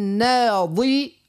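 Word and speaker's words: ناضرة: يعني نقول وجوه يومئذ ناظرة ضاد اللي هي ناظرة إلى ربها ناظرة ضاء ضاء والأخرى ضاد.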